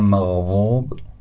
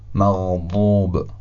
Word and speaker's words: مغضوب: یا کلمه مغضوب، اول میگم مغضوب، حالا مغضوب. کدوم وا بود کدوم واد؟ بعد اولی وا یعنی تنزین بود، خیلی نرم تلفظ شد نسبت به واد، و دومی حرف واد.